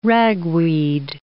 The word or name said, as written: ragweed